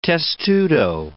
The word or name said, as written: testudo